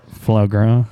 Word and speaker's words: foie gras: That Faux Gray at Otto's. Faux Gras or whatever it's called. I don't know how to say it right. – I'll call it Foie Gras.